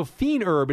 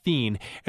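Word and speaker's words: fines herbes: So fiend herb, it's actually spelled F-I-N-E, fine. – Okay. – But it's pronounced fiend.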